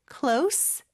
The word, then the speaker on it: close: 나중에 발음할 때도 얘기는 하겠지만 close, C-L-O-S-E 이 단어가 발음이 크게 두 가지가 있어요. 하나는 close, S 발음, 하나 그리고 마지막으로 하나는 close, Z 발음이 있죠.